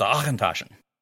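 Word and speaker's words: Auchentoshan: And then he and I shared a glass of whiskey that's spelled A-U-C-H-E-N-T-O-C-H-E-N, if I've got that right. And it's pronounced just the same way it's spelled. It's Achentaschen.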